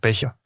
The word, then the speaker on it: Becher: Er det sådan, man udtaler efternavnet? Becher? – Ja, Becher, ja.